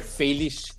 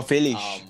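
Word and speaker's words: Félix: Felish, according to the right way to pronounce his surname. But João Felix has been... – Oh, Felish.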